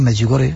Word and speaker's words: Medjugorje: Incluso yo creo que dicen mal el nombre, yo lo digo bien, Medjugorje.